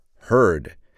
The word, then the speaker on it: herd: W-O-L-F in the singular, W-O-L-V-E-S in the plural. What's the word for a large group of elephants? Herd. Pronounced just like the past tense of the verb to hear, but it's spelled H-E-R-D.